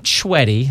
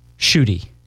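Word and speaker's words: Schuette: Joshua, not Schwetty. That's how I read it. No. – Josh Schutte. – You got it. – Woo! – Which rhymes with? – Booty.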